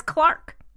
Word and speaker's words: clerk: Clark comes from the English word clerk, C-L-E-R-K, and they pronounce it in England as clerk. – They pronounce it as Clark.